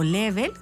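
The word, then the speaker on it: Lebel: Es así como Martin Liebel, o Level, como se pronuncia en francés,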